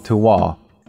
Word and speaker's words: Tehuatl: Tom is currently running a Kickstarter for one of his new projects called Tewahtl, which is spelled T-E-H-U-A-T-L.